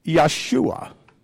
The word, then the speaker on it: Yeshua: He is Yeshua, which is the Aramaic pronunciation of Joshua.